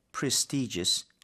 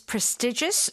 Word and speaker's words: prestigious: 근데 중요한 건 most prestigious law firms. 벌써 느낌이 되게 되게 고급일 것 같아요. – Okay, so you can pronounce this prestigious or prestigious. Both right. – are fine.